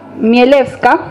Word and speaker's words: Chmielewska: Bueno, Danuta Mielewska, así se pronuncia,